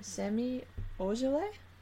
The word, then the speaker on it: Semi Ojeleye: Let me try to say his name. Semi Ojeleye? – Sounds good to me.